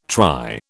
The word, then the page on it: try: British English